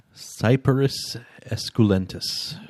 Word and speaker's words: Cyperus esculentus: As our person deemed that can actually pronounce these things, Cyperus esculentus.